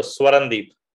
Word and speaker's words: Swarandeep: Almost right. So Swarandeep.